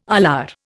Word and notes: alar: Indian

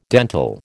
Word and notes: dental: American English

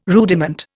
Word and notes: rudiment: Indian

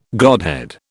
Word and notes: godhead: British English

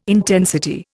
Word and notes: intensity: Indian

